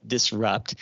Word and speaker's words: DisRxUpt: I have another podcast called Disrupt, but it's spelled D-I-S-R-X-U-P-T. That X is silent, like we often use it here in pharmacy.